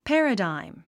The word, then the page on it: paradigm: pǽrədàim; パラダァイム